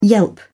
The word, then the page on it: yelp: British English